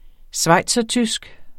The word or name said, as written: schweizertysk